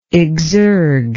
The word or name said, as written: exergual